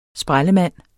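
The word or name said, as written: sprællemand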